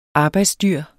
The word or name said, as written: arbejdsdyr